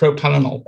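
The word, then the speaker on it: propranolol: You mean propanol? – That's the one. – Sorry, I wasn't correcting that, by the way. I was like, have I got that name wrong? – No, it is propanol.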